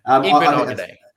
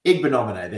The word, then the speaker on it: Igbinoghene: And I always muck up this pronunciation, but Igbinoghene, I think his name. I think it's close to something like that, but Noah, let's just call him Noah Ig. – Igbinoghene. – Igbinoghene, there we go.